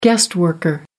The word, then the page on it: guest worker: American English